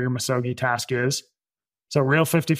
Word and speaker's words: misogi: I don't know if I pronounce it right, um, but it's misogi. – Yeah, misogi.